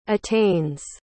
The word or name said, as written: attains